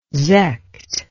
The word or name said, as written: sekt